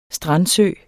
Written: strandsø

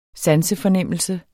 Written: sansefornemmelse

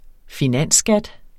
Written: finansskat

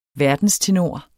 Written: verdenstenor

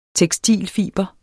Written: tekstilfiber

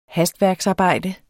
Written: hastværksarbejde

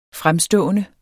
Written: FREMMEST

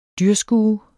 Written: dyrskue

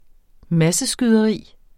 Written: masseskyderi

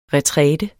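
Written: RETRÆTER

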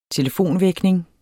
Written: telefonvækning

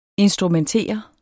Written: instrumentere

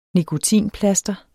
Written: nikotinplaster

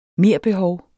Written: merbehov

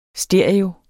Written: stereo

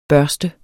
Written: børste

